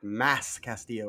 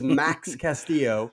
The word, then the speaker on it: Max Castillo: I think last week I called him like Mass Castillo or something like that. It is Max Castillo.